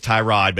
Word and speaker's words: Tyrod: And I know it was Tyrod, but he told people a couple of years ago it's actually pronounced Tyrod.